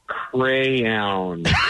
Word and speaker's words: crayon: Yes, it's crayon. – Crayon. – It's crayon. I've been saying that wrong for years.